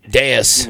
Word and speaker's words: Deus: It's the Deuce Cafe, I believe it is. The owner, maybe, possibly. It's sponsored by Deuce, but it's like... Totally, just Deus, you know. Deus Ex Machina is the proper way to say that.